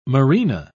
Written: Marina

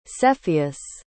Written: cepheus

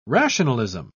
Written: rationalism

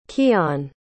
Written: keyon

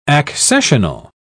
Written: accessional

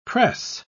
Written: press

